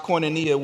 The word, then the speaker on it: koinonia: Fellowship in the Greek is pronounced koinonia, which means commonship.